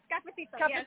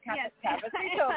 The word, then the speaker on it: Cafecito: Cafecito. Is that it? – Cafecito. – Cafecito.